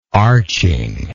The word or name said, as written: arching